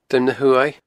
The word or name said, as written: Druim na h-Uaimh